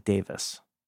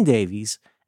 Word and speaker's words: Davies: In England they pronounce that Davis.